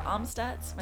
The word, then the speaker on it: Amstutz: All of my social media handles are at Sandra Amstutz.